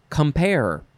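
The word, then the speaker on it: compère: Okay, next word is compare. This is not the word where you're comparing and contrasting. This is spelled C-O-M-P-E-R-E, or you can put an accent on the first E, which is up on the left and down on the right.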